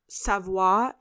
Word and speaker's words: Savoir: I also want to let you guys know that I just butchered a couple moments ago. It's Savoir is the PR firm and Maison Laveau is Antoine's company.